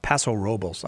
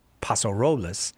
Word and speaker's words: Paso Robles: People in the Southern California say Paso Robles, and people in the North say Paso Robles. And I'm guessing Jim says Paso Robles.